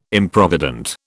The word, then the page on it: Improvident: British English